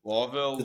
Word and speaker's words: Louisville: That's how everyone else pronounces it, but in Louisville, you can't say Louis, it's like Lawville or Lawville.